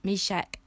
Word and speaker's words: Meshach: How do you say their names? Shadrach, Meshach, and Abednego. That's how you say it.